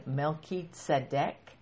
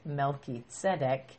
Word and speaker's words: Melchizedek: For starters, that might be a new word for you, a new name for you to hear, Melchizedek. That's the pretty much the Greek way to pronunciate it, to pronounce it. In Hebrew, it would have been Melchizedek.